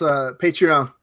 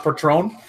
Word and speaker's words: Patreon: It's Patreon. – It's not Patron? – No, it's not Patron.